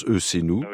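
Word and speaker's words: ECNou: Ça s'écrit E majuscule C majuscule N majuscule O U minuscule. On prononce E c'est nous.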